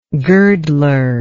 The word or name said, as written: girdler